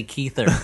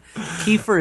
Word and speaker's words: Kiefer: It's impossible to not say Keither when you say his name. Kiefer is such a weird name.